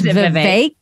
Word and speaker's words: Vivek: I just realized that I've been pronouncing it wrong this whole time. It's Vivek. Vivek.